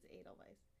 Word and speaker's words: Edelweiss: I mean, it is technically in America, probably Edelweiss, but because we don't pronounce the W like a V.